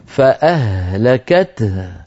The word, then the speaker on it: فأهلكته: فأهلكته، الهاءان تستنفد الهواء استنفادا من الرئتين، لذلك اضطررت أن أخف فأرجع فأقول أصابت حرث قوم ظلموا أنفسهم فأهلكته، حتى آتي بالهائين مبينا لهما كما نبه على ذلك أئمتنا.